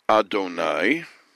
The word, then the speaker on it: Adonai: We pronounce it Adonai, and when we speak about it, we say Hashem.